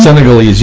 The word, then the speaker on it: Senegalese: Senegalese man. – Senegalese.